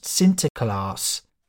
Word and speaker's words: Sinterklaas: It's easier to grasp when you realise that in the Netherlands, Saint Nicholas is pronounced Sinterklaas.